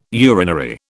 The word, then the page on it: urinary: British English